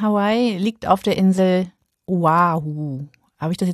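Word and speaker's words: Oahu (Oʻahu): Dieses kleine umgedrehte Apostroph zwischen dem O und dem A, das heißt Okina und ist so eine Art Stimmabsatz, so wie bei Hawaii. Also eigentlich müsste es Oahu und Hawaii heißen.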